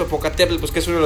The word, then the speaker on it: Popocatépetl: Popocatépetl.